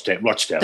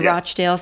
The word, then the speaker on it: Rochdale: which is Rochdale. Is that how you pronounce it? – Rochdale. – The Rochdale child sex abuse ring.